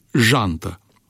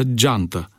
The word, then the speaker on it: jantă: Vă vorbeam și cu altă ocazie despre substantivul jantă, pe care majoritatea șoferilor îl pronunță geantă, tot așa cum le vine mai ușor să pronunțe marșalier în loc de marșarier.